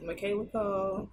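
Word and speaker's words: Michaela Coel: Oh, that's how you say her name? Yes. I thought it was always Michaela. – Nah, her name is Michaela Cole.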